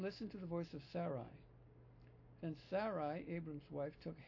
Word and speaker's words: Sarai: Hence, Sarai, with the emphasis on the first syllable, said to Abram, please now, Jehovah has shut me off from bearing children.